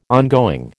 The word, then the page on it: ongoing: American English